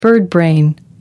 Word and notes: birdbrain: American English

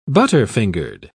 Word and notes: butterfingered: British English

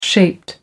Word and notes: shaped: American English

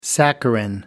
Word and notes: saccharin: American English

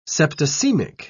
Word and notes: septicaemic: British English

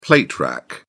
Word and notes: plate rack: British English

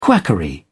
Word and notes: quackery: British English

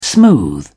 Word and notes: smooth: British English

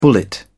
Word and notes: bullet: British English

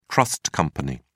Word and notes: trust company: British English

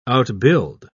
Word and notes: outbuild: British English

